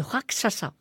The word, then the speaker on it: Rakshasa: Et il est entouré de Raksasa. – de Rakshasa.